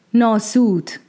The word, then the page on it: Naẓar: Glance